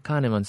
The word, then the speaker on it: Kahneman: juicio y lo enseña un tal de Can, no, no, lo pronuncio bien. Me dice, ah, Kahneman, sí, él es muy famoso, es un tipo muy famoso, toma su curso.